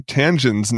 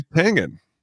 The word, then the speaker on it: Tangen: You said, and as we're just about to getting ready to record, that we've been pronouncing Jason Tangen's name wrong this entire time, like our 5-year history of doing this show and talking about many articles that he's co-written. Yep. That it's actually Tangen. – Yeah, it's Tangen, like Glenn Langenberg.